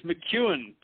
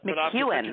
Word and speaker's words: McKeown: And am I pronouncing that right? – It's McKeown, but McKeown. Okay, great.